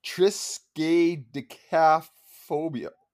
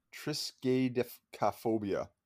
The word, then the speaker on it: triskaidekaphobia: So, right and then there's a phobia of the number 13 i'm going to try to say it triskaidekaphobia wow i butchered that it's tris triskaidekaphobia hmm.